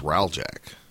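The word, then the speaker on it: Hreljac: It's H-R-E-L-J-A-C. It's probably just Raljack. – Could be. – But the H is silent.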